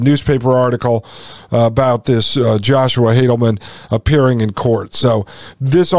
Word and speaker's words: Haldeman: It says, on October 13th, 1940, a Regina chiropractor named Joshua Haldeman, and that's, I've heard people pronounce it different ways. It's H-A-L-D-E-M-A-N. So I'll say Joshua Haldeman, appeared in city court to face two charges under the Defense of Canada Act.